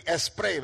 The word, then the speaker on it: spray: Nosotros decimos aquí spray, ¿verdad? Porque la S la pronunciamos como es.